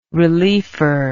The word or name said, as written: reliefer